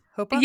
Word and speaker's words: chuppah: So it's the chuppah? Chuppah? – Yeah, the chuppah.